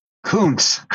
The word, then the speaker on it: Kuntz: Kuntz, like the writer, Dinar Kuntz.